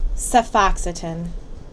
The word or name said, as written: Cefoxitin